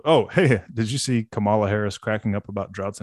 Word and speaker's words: Kamala: By the way, to the guy who had the comment about the pronunciation of Kamala, thank you for chiming in. – Kamala. – That's right. Clearly, it's Kamala.